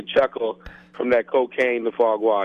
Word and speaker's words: foie gras: We are talking to Chef Jeff Henderson, author of Cooked, From the Streets to the Stove, From Cocaine to Foie Gras.